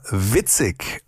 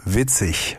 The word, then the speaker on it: witzig: Also ich kann euch sagen, zumindest die 10 Minuten, die ich jetzt in der Praxis schon mal geprobt habe, die sind sehr witzig und das war natürlich falsch ausgesprochen, weil es heißt witzig mit IG. Das deutsche IG wird ICH gesprochen.